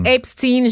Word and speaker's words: Epstein: Epstein, je, savais que j'y arriverais pas du premier coup.